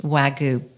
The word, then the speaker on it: Wagyu: But I've never really experienced Wagyu beef, which is spelled W-A-G-Y-U. – Yes, ma'am. – I guess I'm saying it right. – Yes, you are.